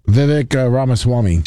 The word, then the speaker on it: Vivek Ramaswamy: Vivek Ramaswamy.